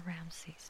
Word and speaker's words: Ramses: in the land of Rameses or Ramses, depending on where you're from and how it's pronounced, as Pharaoh had ordered.